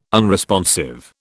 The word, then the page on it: unresponsive: British English